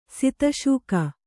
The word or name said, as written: ಸಿತಶೂಕ